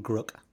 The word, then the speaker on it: Grk: I pronounce him Grook. – Grook. – But you can pronounce him however you like. And thank you. – Some people say Grook or Gurk, or quite a lot of people call him GRK, which I've never really understood.